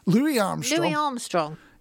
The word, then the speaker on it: Louis Armstrong: Have I said that right? – Louis Armstrong. He was the one to all the time in the world.